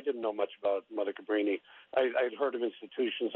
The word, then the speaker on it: Cabrini: Am I pronouncing it right? Cabrini? – Cabrini, that's right, Cabrini, yeah.